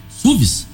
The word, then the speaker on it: SUV: Suéves? Como é que é? SUVs? É SUVs? – SUV. – SUV, SUV. – Tem gente que fala SUV.